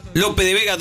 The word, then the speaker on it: Lope de Vega: Recordemos que de premios tenemos una cena de mar de la cocina de Ana Mellino para 5 personas en López Vega 2698. Lo dije bien, sí. Porque se me mezclaba con lo que era el club de la Milanesa. Tengo las direcciones en la cabeza ya. Lope de Vega 2698.